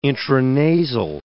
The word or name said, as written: intranasal